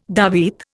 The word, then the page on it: davit: Indian